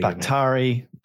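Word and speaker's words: Bakhtiari: Somebody put the phonetic spelling. Buktari.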